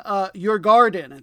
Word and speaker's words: Djurgården: It is number 12 in last place, Växjö, which is spelled V-A-X-J-O, but was the one pronunciation I decided to look up, versus number 11, Djurgården. It's got a silent J in there. And, well, a soft J, I should say.